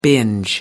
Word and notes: binge: American English

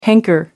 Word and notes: hanker: British English

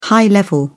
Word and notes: high-level: British English

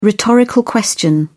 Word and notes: rhetorical question: British English

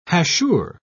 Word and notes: hachure: British English

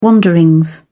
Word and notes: wanderings: British English

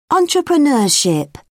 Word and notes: entrepreneurship: British English

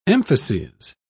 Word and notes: emphases: British English

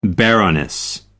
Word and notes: baroness: American English

